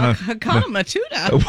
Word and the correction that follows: Hakuna Matata: It's Hakuna Matata.